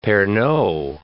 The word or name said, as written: Pernod